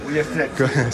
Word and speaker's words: Ullastret: Pues estamos aquí en Ullastret, ¿lo he dicho bien? – Sí, Ullastret.